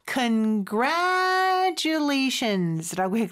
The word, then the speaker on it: congratulations: Congratulations라고 했는데 Congratulations라고 하고 싶을 거예요. 근데 이 TU가 합쳐지면 추 발음이 나잖아요. 근데 여기는 T 발음보다는 그냥 지읒 발음처럼 해주는 경우가 많습니다. 그리고 gra 이 부분에 강세가 있어요. Congratulations 또 더 강한 강세가 또 뒤에 있죠.